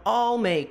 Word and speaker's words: I'll: Fica com uma palavra parecido com a palavra, parecido não, igual a palavra all, tá? Então, I'll make some new friends, ok?